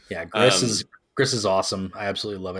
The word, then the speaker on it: Gris: Is that how you pronounce that? – I believe it is Gris. Yeah, Gris. – G-R-I-S. Yes, another game that came off the Humble.